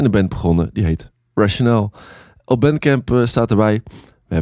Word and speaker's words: Rationale: Ehm, hoe spreek je dat uit? Rationale? – Ja.